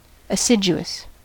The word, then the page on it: assiduous: American English